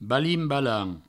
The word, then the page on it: balin-balant: Provençal